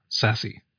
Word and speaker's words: SASI: Matt, also in our chat, has confirmed that Shugart were pushing for this SCSI to be pronounced sexy, to follow on from sassy, S-A-S-I.